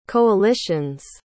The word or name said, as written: coalitions